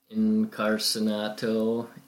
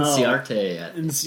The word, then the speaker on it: Inciarte: He could be batting in between Encarnacionato. How do you say his last name? – I don't know. – Inciarte.